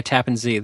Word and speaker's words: Tappan Zee: So I knew that Rob lived right in that area, right by Tappan Zee. That's the correct pronunciation of it. Yes.